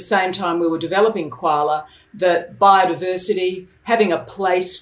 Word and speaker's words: Kwala: Some people might think that the app is called Kwala, which of course is always associated with Australia, but it's you actually just enlightened me and said, no, it's two syllables. It's Kwala.